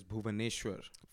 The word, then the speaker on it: Bhubaneswar: The name of the city is Bhubaneswar.